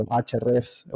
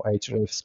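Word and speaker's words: Ahrefs: Yo la verdad es que veo cuando, cuando HRFs o HRFs, como a cada uno le gusta pronunciarlo, mm.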